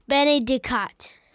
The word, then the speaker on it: Benedicat: And he underlined one of the words, Benedict. How do you say that again? – Benedicat.